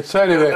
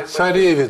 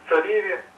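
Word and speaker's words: Цареви: Придите, поклоняйтесь цареве нашему Богу. – Не цареве, а цареве. Там ударение есть. Читайте внимательнее. – Придите, поклонимся цареве нашему Богу.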